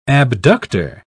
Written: abductor

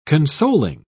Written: consoling

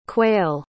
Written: quayle

